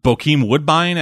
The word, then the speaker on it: Bokeem Woodbine: It showed off a couple seconds of Bokeem Woodbine as Shocker.